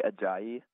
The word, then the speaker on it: Ajayi: And can I just compliment your pronunciation of Jay Aji? I totally botched that just now trying to compliment you. But can you just say that again for us? – Jay Ajayi.